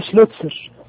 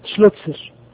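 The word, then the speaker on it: Schlözer: قلنا العالم يعني اسمه ايه؟ شلوتسر، شلوتسر، مع ان اسمه ما فيهوش تاء ولا في سين، اسمه بيكتب كده بالانجليزي بالحروف ال شلوتسر كده، هناك السماء صور كده، حرف الزد ينطق تاء سين، كذلك حرف الصاد هم بيقرأوه تاء سين.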